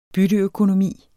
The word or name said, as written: bytteøkonomi